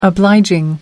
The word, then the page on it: obliging: American English